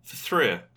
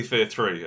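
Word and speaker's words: F.3.A.R.: Like the guy from Fthria. – Fthria. Yes. – Well, technically Fear 3, as it was, yes, as it's supposed to be pronounced.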